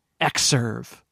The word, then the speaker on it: Xserve: It was OS X on a server, Xserve, pronounced like the letter, not the Roman numeral, OS X, X.